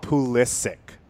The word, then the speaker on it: Pulisic: He wants to be called Pulisic.